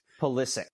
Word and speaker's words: Pulisic: But is it Pulisic or Pulisic? How do they pronounce the U in his name? I know that the first I is emphasized. Yeah. – Pulisic. Christian Pulisic. Pennsylvania Pulisic.